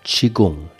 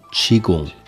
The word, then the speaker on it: Qigong: Sagt ja auch Qigong, nicht Qigong. Man sagt Qigong. Dieses O von G-O-N-G wird ausgesprochen wie ein U. Qigong.